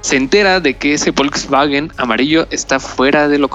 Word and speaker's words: Volkswagen: De hecho, lo dije mal, es Volkswagen, porque es Volkswagen es auto.